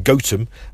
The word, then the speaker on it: Gotham: He took it from a small village in Nottinghamshire that he once visited, actually pronounced Gotham.